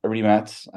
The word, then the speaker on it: Rimac: Rimac.